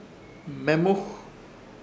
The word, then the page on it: Hmemuj: Ishir Chamacoco